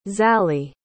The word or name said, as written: Zali